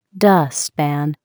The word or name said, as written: dustpan